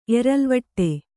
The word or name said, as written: ಎರಲ್ವಟ್ಟೆ